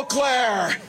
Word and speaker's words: Eau Claire: Hello, Eau Claire.